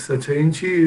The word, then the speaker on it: Sacha Inchi: You found Sacha Inchi, is that the proper way to say it? – Yes, Sacha Inchi is the name of the seed in Quechua, which is the language of the Andes, which okay.